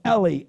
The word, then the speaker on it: Eli: And the Aramaic is actually pronounced Eloi, Eloi. The Hebrew is pronounced Eli, Eli.